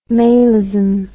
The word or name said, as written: malism